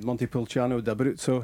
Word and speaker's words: Montepulciano d'Abruzzo: I was going to say Paolo Di Canio would really approve of that red Montepulciano d'Abruzzo. He would. – Pronunciation. Yes.